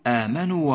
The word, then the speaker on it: آمنوا: آمنوا وعملوا، إياك أن تبتر حرف المد من القراءة، أي لا تسقط حرف الواو من النطق في كلمة آمنوا فتقول آمنوا وعملوا، بل الصواب أن تقول والذين آمنوا وعملوا، فللحرف ميزان فلا تك طاغيا فيه ولا تك مخسر الميزان.